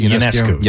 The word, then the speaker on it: Ionescu: Is that how you pronounce it? – Ionescu. – Yes.